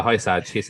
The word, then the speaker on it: Hysaj: Um, it doesn't say his name right, but it's H Y S A J is his name. – Hi, Yeah, hi Saj.